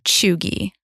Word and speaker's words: cheugy: It's spelled C-H-E-U-G-Y. It is fun to say. – It is kind of fun to say. – Chuggy.